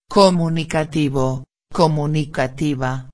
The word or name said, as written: comunicativo-a